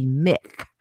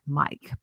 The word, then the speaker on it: Myq: And his name is spelled M-Y-Q, which I thought initially was, when you first look at it, it should be, you think it would be Mick, but it's Mike. See, that shows he has a sense of humor right there, M-Y-Q.